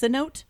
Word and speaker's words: cenote: We go deep in the depths of the jungle to a cenote. I think that's what how they're pronounced. Cenote. It's like an underground jungly kind of a pool. – And cenote. – Cenote. There it is.